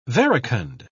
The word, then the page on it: verecund: British English